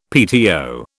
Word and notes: PTO: British English

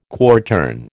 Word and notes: quartern: American English

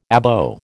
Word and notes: Abo: American English

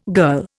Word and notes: girl: Indian

